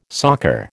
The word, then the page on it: soccer: American English